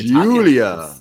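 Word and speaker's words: Giulia: It's G-I-U-L-I-A. It's the Julia. – Italian.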